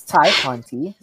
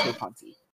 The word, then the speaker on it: Tay Conti: So, it's Ty Conti. So, whoever says Tay, that's a mortal sin, okay? For the Divas rule book. No more saying Tay Conti.